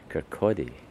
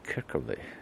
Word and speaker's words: Kirkcaldy: But it was lovely over there in Kirkcaldy, as the people say. Kirkcaldy is the proper pronunciation. Some say Kirkcaldy, and Kinghorn and Burnt Island.